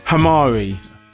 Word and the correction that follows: Kamari: Kamari. – Oh, Kamari, yeah. – Kamari, Hamari. Yeah. I'm not sure if you pronounce the K anymore. – Yes, Kamari. Obviously, okay, him.